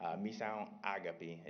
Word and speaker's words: Missão: We also were able to visit their after-school community center as well, and they named it Mission Agape, or Missão Agape is how you pronounce it in Portuguese, and it started off just to be a before and after-school program for the youth.